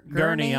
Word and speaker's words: Gurnee: Gurnee, Illinois.